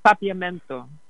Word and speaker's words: Papiamento: And that actually goes to the next question I had, which was, you keep mentioning Papiamento. I'm not sure if I'm saying it right. – Papiamento. Yes, that is the indigenous African blend language.